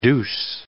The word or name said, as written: douce